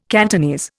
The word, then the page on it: Cantonese: Indian